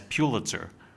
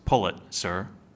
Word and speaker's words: Pulitzer: The name Pulitzer is often mispronounced as Pulitzer. The correct pronunciation, according to administrators of the prize, should sound like the phrase, "Pull it, sir."